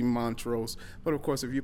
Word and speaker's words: Montrose: What's the proper pronunciation of Montrose? – Montrose. – Yeah. So I'm saying it right, as far as you know? – As far as I know.